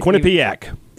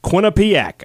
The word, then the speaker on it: Quinnipiac: Quinnipiac. – That's right. Did you just say it two different ways? – Quinnipiac.